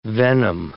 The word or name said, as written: venom